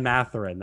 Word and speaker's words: Mathurin: And then 9 and 10, I think a name like Benedict Mathurin, if that's how I pronounce it. – Ben Mathurin. Yes. – Mathurin, I think that's how that would go.